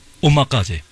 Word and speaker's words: omakase: Omakase.